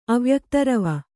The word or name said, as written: ಅವ್ಯಕ್ತರವ